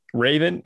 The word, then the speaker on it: Raevn: And this beer that I'm drinking is called Raven, but it's spelled weird. It's spelled R-A-E-V-N. Raven is a black lager.